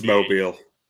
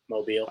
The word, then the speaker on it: Mobile: It's Mobile. – Mobile. – I've been there before. Not the Senior Bowl, but I've been through Mobile before. It's Mobile.